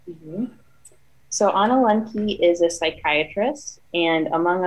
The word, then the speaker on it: Lembke: Lembke.